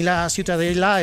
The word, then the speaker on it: Ciutadella: Para mí la Ciutadella es...